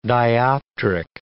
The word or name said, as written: dioptric